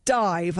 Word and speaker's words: DIIV: So I'm going to play something else from a shortly to be released album from Dive, as I recently learned how to say it, D-I-I-V. Apparently that's how you say it. The album Zerp, with an exclamation mark spelt Z-I-R-P, is out at the end of the month.